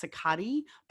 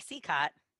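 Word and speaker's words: Cicotte: I have always heard his name pronounced Sicati, but apparently it's also pronounced Sicat. – I've heard it Eddie Sicott. – Okay. See, there's like five different pronunciations.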